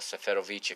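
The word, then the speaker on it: Seferović: I think João Félix, more than anyone, is missing Seferovic right now. Or Seferovic, if you prefer the Portuguese-sized pronunciation.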